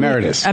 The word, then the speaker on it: emeritus: We have Professor Alan Dershowitz, a leading constitutional lawyer in the country, Harvard Law School emeritus. Did I say that correctly? – Emeritus. – Emeritus. I always say it wrong.